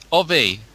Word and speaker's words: Ovi: Well, I was told on day one when Ovi was launched, I pronounced it Ovi somewhere, and I had some incensed people from Finland saying, no, it's Ovi. And I got so told off and burned, I've remembered ever since. But that is the correct pronunciation.